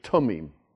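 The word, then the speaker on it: tumim (תומים): In Hebrew, at the beginning of a syllable, a beginning of the word, a B, G, D, T, K, these, we call them begad kafath letters, are always hard, not soft. So it has to be tumim, not thummim.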